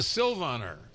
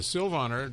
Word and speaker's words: Silvaner: It's a Silvaner, S-I-L-V-A-N-E-R, also spelled with a Y. – And this is German. – It's a German Trocken, completely dry. And it's a Sylvaner. That's the name of the grape variety. And this one is from Rudolph May, M-A-Y.